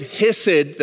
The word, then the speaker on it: hesed: That word hesed that I just gave you there is spelled H-E-S-E-D, hesed.